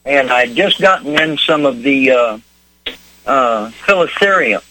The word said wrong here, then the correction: helichrysum: Helicerium? Is that how you say it, Leon? – Oh, helichrysum. Yes, sir. Helichrysum.